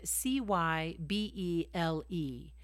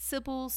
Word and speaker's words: Cybele: And you spell her name C Y B E L E. Some people pronounce it Sybil, some pronounce it Kibeli, some pronounce her Cybele, but either way, you see this mother worship come back in.